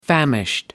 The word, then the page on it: famished: American English